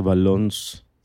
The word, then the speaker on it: Valence: O como se pronuncia, Valons. Valencia, pues, pero en francés.